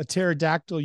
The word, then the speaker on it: pterodactyl: Why can't you hear a pterodactyl using the bathroom? The P is silent.